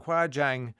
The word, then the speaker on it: Huajiang: Huajiang Grand Canyon Bridge.